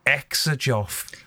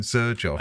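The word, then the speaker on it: Xerjoff: And then there's this Scouse girl who pronounces it Exajoff. – Yeah, I have seen her videos. Exajoff. – Just wrong. Okay, well, that does surprise me that you have 14 Zerjoff.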